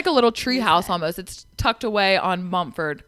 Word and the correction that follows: Montford: I always say that wrong. Montford.